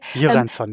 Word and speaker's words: Göransson: Wird wie J ausgesprochen, das G. – Göransson, ja.